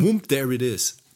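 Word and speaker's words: Whoomp There It Is: Who, who, whomp, there, wo, eigentlich whomp, whomp There It Is. Whomp There It Is, ja, genau. Ja. Scheiße, guck mal, die ganze Zeit falsch ausgesprochen.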